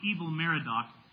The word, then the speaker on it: Evil-Merodach: After Nebuchadnezzar died, he was first replaced by one of his sons named, it's pronounced Evil-Merodach. His name was not Merodach and he was really evil, okay? If we were to really say it, it would be Evil or Ewil.